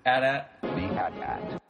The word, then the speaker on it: AT-AT: And correct me if I'm wrong, but did the Haunted Mansion-sounding voiceover guy pronounce it AT-AT? – Yes, and you know why? Because that is actually the British pronunciation.